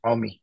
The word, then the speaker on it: Omi: it's Omi, yeah, Omi, all right.